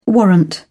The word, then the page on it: warrant: British English